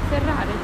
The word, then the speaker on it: Ferrari: Ferrari. – Ah, Ferrari.